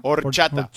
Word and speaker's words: horchata: Horchata. Is that how you say that? – Horchata. H is silent.